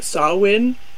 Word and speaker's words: Samhain: Many people have probably seen the word Samhain, which I didn't know until looking this up, is actually pronounced Samhain, because Gaelic ain't make no goddamn sense.